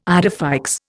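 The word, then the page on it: idée fixe: Indian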